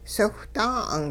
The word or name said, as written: siwhdaa'ung